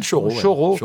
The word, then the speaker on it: choro: Et notamment le choro. Si je prononce bien, on dit choro. – Oui, c'est le choro.